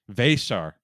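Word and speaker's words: Veesaar: The most important thing that I gleaned from that is it's Vesar is how you pronounce Henry's last name. Vesar, not Vesar.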